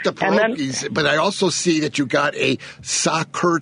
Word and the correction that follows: Sacher: tort class coming up. Is that did I pronounce it right, Sacher? – Sacher tort, yes. Exactly.